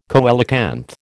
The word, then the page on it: coelacanth: American English